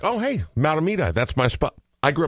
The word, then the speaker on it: Mahtomedi: Mahtomedi. – Yeah. – I can even spell it. – M-A-H-T-O-M-E-D-I.